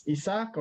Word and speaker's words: Isaac: My name is Isak or Isaac, depending on how you want to pronounce my name.